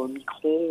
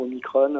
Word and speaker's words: Omicron: Oui, oui, c'est vrai, euh, ça commence à se retourner, mais on ne sait pas exactement quel sera l'effet du variant Omicron ou Omicron, euh, ça dépend, on peut prononcer les deux, je crois. Euh,